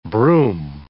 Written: broom

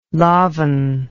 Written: lofn

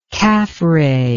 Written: kafre

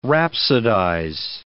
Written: rhapsodize

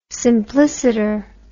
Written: simpliciter

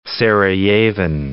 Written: Sarajevan

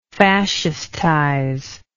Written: fascistization

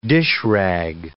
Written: dishrag

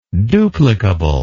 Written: duplicable

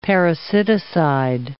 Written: parasiticide

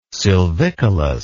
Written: silvicolous

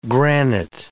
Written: granite

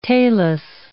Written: talus

